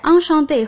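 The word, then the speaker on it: enchantée: Enchanté de vous connaître. – 很高兴认识您。不过听众朋友请注意，说话人是男性时用 enchanté，而说话人是女性时则要在 enchanté 后面加上一个 a，读音不变。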